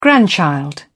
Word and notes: grandchild: British English

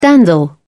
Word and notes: dandle: British English

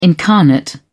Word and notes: incarnate: British English

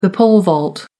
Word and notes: pole vault: American English

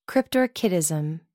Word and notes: cryptorchidism: American English

